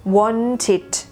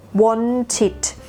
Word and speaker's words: wanted: wanted wanted สระอีดอเด็กนะคะขอย้ำไม่ใช่สระเอสระเอกนะคะไม่ใช่นะไม่ใช่ wanted